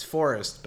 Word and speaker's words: fForest: Yes, F Forest West. It's pronounced Forest. The first F is silent.